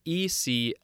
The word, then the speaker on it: Eclogues: It's probably from the Latin name of a shepherdess in Virgil's Eclogues. Oh, I'm sure I said that wrong. Eclogues. Next, we have the word amass, A-M-A-S-S.